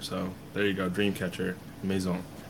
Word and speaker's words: Maison: But my first pick will have to be Dreamcatcher Maison. You know, a little French pronunciation for you.